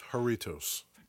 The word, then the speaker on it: Jarritos: He was frustrated with our pronunciation of... – Jarritos. Yeah. – He wants us to know that it is Jarritos. – Jarritos.